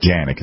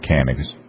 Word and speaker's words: Canik: And it is Janik, it's not Canik. The C is pronounced as a J.